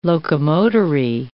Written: locomotory

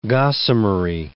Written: gossamery

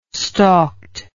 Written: stalked

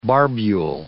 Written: barbule

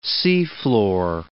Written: seafloor

